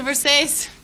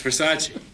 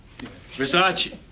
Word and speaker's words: Versace: It's a Versace. – It's Versace. – What? – Versace. It's pronounced Versace.